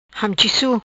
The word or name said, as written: Hamchisu